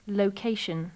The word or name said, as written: location